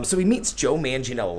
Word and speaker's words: Manganiello: So the biggest thing is he meets Joe Manganiello. See, I learned how to say it. Thank you, Pee-wee. – Yeah, Joe Manganiello.